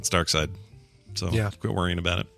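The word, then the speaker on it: Darkseid: Just a quick note to say I found multiple sources that confirmed Jack Kirby himself pronounced it Dark Side. He says it is a Germanic pronunciation of the villain's name. – So actually Dark Side, not Dark Seed.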